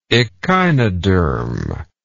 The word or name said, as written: echinodermal